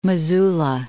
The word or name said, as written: Missoula